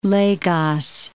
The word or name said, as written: Lagos